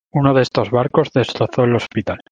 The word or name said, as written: barcos